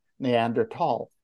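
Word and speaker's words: Neandertal: In English, it's TH, but it's pronounced in German with a T, Neander Tall.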